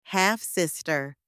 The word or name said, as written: half-sister